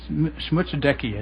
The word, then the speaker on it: Schmutzdecke: I think you say two E's at the end, though it's not spelled that way. Schmutzdecky, I think, is correct.